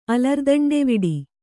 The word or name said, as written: ಅಲರ್ದಂಡೆವಿಡಿ